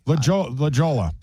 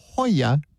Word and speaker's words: La Jolla: La Jolla. – Yeah. It's the same thing. La Joella, J-O-L-L-A.